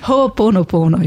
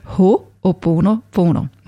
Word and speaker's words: Ho'oponopono: Ho'oponopono ist das. – Darf ich das nicht mehr wissen? – Ho'oponopono.